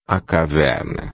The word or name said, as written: caverna